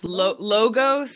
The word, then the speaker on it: logos: But the word epidemiology comes from the Greek words epi, meaning on or upon, demos, meaning people, and I don't know, do you pronounce this logos? – Logos, yeah. – Logos, okay. Meaning the study of.